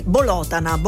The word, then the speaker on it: Bolotana: ma in particolare alle donne di Bolotana con l'accento sulla seconda O.